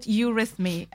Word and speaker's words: Eurythmy: So it's called Eurythmy, and that is Greek and stands for, translated as the beautiful rhythm.